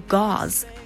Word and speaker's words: gauze: 虽然这个单词看起来很难念的样子，但其实呢只有一个音节，读作 gauze。